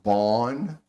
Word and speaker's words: Bonn: from Bonn, Germany, or is it pronounced a different way? – Bond's fine.